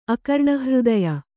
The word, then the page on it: ಅಕರ್ಣಹೃದಯ: akarṇahřdaya